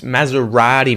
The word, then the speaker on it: Maserati: No, it's Maserati, mate. Maserati. – Maserati.